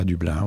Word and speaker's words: Dublin: À Dublin, oui, on le prononce comme ça à la française.